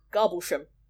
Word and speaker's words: Garboldisham: There's a place in Suffolk that looks like it's spelt Garboldisham. – What? – But it's pronounced Garblesham.